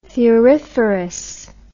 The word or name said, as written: thuriferous